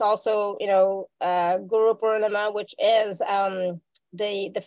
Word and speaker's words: Guru Purnima: Guru Purnima.